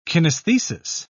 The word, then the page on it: kinesthesis: British English